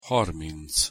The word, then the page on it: harminc: Hungarian